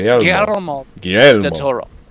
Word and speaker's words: Guillermo del Toro: Rolls in the back of your mouth. Guillermo del Toro. Almost, don't roll it, but get close to it.